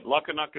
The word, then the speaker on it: Lukinuk: Scott Sillari, and I'm excited to have, and I'm going to butcher your last name, Dr. Doug, but Dr. Doug Lukinuk? – Yeah, pretty good. Lukinuk is the way it goes, but you won't be the first or last to butcher that one.